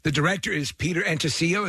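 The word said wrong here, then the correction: Atencio: Is that how it's pronounced? – Peter Intensio.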